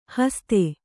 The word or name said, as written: ಹಸಿತ